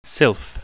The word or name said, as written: sylph